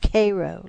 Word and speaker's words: Cairo: Cairo, Illinois, pronounced Cairo, like the syrup.